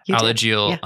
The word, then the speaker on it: Alagille: So you said you started working with the Alagille. Did I say that right? – Yeah. – Alagille yeah.